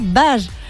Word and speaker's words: Bages: Alors, j'hésite toujours sur la prononciation. Je ne sais pas si c'est à Bages ou Bagès dans l'Aude. En tout cas, j'espère que vous m'en excuserez. Bages, voilà, Marc. Ben voilà, Bages. C'est Marc qui me rectifie.